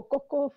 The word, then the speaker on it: Koko: Whakalofalaiatu ki motoluasi, ko hingoa haku ko koko foi mana tawha. My name is not cocoa like melon or powder, nor is it cacao like the seed, it's koko. Say it properly.